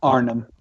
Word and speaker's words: Arnim: And he's facing number 9 seed, Arnim. Is that how you say it? Arnim? – Arnim.